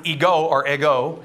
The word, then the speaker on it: ego: The Greek word for I is ego, E-G-O. Interesting, isn't it? The ego, it's not like the muffin, it's ego, that's how you pronounce it.